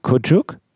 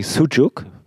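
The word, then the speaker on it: Küçük: Das ist Mavi Kucuk. Ich hoffe, ich spreche das jetzt richtig aus. Wir haben das jedenfalls früher mal falsch ausgesprochen und haben gesagt Mavi Sucuk, was so viel heißt wie blaues Würstchen.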